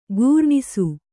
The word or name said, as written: ಖುರಣಸ